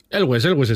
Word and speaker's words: Elwes: Bueno, nunca supe cómo se dice el apellido. – Elwes está bien dicho. – Elwes, vale. – Yo con mi gran conocimiento acerca del inglés, yo diría que Elwes es la mejor manera de pronunciarlo.